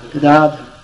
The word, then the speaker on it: Gadadhar: The member of the Panchatattva, whose name begins with G, his name is pronounced Gadadhar. – Shri Advaita Gadadhar Shiva Sadhu. Actually, Prabhupada used to say, Shri Advaita Gadadhar Shiva Sadhu Gaur Bhakta Vrindavan. You must have heard the tape. Somehow or other, we say, Shri Advaita Gadadhar. No, actually, we say, Shri Advaita Gadadhara. Anyway, pronounced Gadadhar.